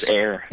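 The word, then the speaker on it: Eyre: Um, and also, uh, not the least of which, uh, your executive producers Terrence Malick, Natalie Portman, and you mes- you mentioned Chris Eyer. Ear. How do you say? It? I'm sorry, Chris Air. Air.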